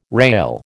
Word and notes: rale: American English

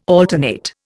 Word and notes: alternate: Indian

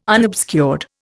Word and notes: unobscured: Indian

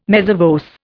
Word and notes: mezza voce: Indian

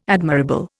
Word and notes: admirable: Indian

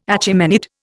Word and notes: Achaemenid: Indian

